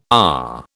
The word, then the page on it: AH: British English